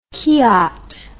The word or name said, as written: kiaat